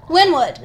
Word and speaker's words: Wynwood: Wildwood, Florida? Did I say that right? Wynwood. – Wynwood.